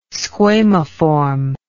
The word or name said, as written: squamiform